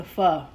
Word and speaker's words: pho: but um you know, if you have any places like pho places and pho, excuse me, I keep pronouncing it wrong. Yeah. – Mm-hmm. Pho.